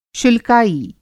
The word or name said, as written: शुल्काई